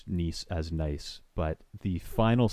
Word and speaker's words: gneiss: there's largely gneiss, but it was for the most part pronounced Nice. at least that was how we were told to pronounce it in interpreting it outdoors.